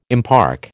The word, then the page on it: impark: American English